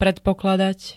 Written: predpokladať

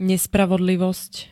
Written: nespravodlivost

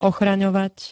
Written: ochraňovať

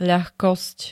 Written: ľahkosť

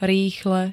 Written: rýchle

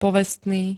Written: povestný

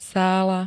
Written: sála

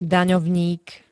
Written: daňovník

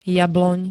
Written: jabloň

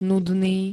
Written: nudný

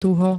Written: tuho

